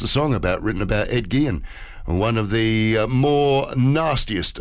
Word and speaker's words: Gein: Edward Theodore Gein, it's pronounced, known as the Butcher of Plainfield or the Plainfield Ghoul.